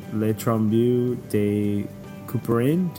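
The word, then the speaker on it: Le Tombeau de Couperin: So some of the songs that you heard were Television's Marquee Moon, Cloud Becomes Your Hand, Garden of the Ape, Ravel's Le Trombeau de Couperin.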